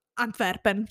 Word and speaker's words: Antwerpen: invece in olandese, in belga olandese era mm, Antwerpen, cioè non avevo mai sentito eh, nominare assolutamente.